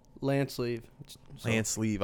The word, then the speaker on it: Lanceleve: Lanceleve.